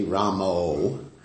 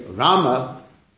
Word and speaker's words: Rama: It's not Hare Ramo, that's someone else. I don't know who that is, but it's someone else. If you pronounce the word differently, it's a different person.